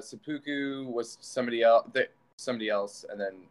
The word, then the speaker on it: Seppuku: Yeah, like right before, because like when I was looking everything up, I was looking up like your music videos, like for like Seppuku, is that how you say it? – Yeah. – Seppuku. Yeah.